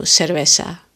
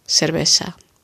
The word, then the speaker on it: cerveza: Daar zeggen ze sowieso cerveza. Ehm, ongeveer cerveza. Una cerveza. Daar worden alle th en th-klanken meer als s uitgesproken.